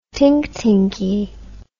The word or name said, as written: tinktinkie